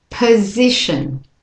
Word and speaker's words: position: So here, the O in the POS part of the word. That O is a schwa. So I'm not saying position, it's p, again, that's the weak syllable. This it in the middle is this strong vowel stress syllable, and the shin is another weak one. So position. And again, position.